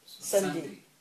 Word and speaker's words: sandhi: Sunday. S-A-N-D-H-I.